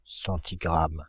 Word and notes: centigramme: [sɑ̃.ti.ɡʁam]; /sɑ̃.ti.ɡʁam/

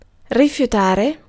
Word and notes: rejeter: [ʁǝʒ.te]; /ʁəʒəte/; /rə.ʒə.te/; /rəʒ.te/

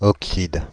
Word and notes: oxyde: Paris; /ɔk.sid/

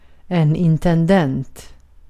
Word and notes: administrator: American English; /ədˈmɪnɪstɹeɪtɚ/

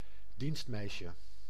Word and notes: bonne: France; /ˈbɔn/

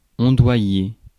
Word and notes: ondoyer: France; /ɔ̃.dwa.je/

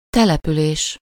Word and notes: colonie: France; [ko.lo.ni]

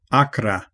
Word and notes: tranchant: France; /tʁɑ̃.ʃɑ̃/

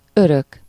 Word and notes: eeuwig: /ɪːʋəχ/

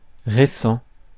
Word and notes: fresh: [ˈfrɛʃ]; /fɹɛʃ/

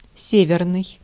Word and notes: northern: /ˈnɔɹ.ðɚn/; /ˈnɔːðn̩/; /ˈnɔː.ðən/

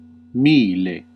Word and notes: mille: Latin

